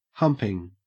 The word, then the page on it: humping: Australian English